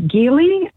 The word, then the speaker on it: Geely: It is a hard G, not a soft G, but most people say Geely.